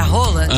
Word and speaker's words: Haaland: Lembra que a gente falou sobre a pronúncia do nome do Haaland, que era Haaland? – Aham.